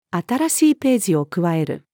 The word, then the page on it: 新しいページを加える: female